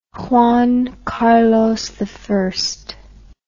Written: juan carlos i

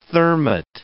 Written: Thermit

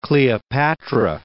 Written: Cleopatra

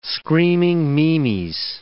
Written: screaming meemies

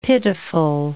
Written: pitiful

